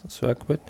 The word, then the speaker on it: Circuit: Ja, es geht um Mario Kart Live Home Circuit oder wie spricht man das aus? Circuit.